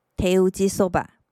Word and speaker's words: 手打ちそば: 手打ちうどん，手打ちうどん，手工蕎麦面就是“ 手打ちそば”。手打ちそば，手打ちそば。